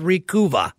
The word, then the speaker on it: Recuva: They also make a product called Recuva. R-E-C-U-V-A. I think it's kind of like supposed to rhyme with recover.